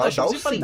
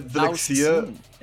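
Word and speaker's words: Dhalsim: É Dalshin ou Dalshin? Dalsin, Dalshin? É porque o H tá depois do D, né? O, H, o H é depois do D, é. Ah, é mesmo, tem verdade. É, eu tava falando errado. Tava falando errado esse tempo inteiro.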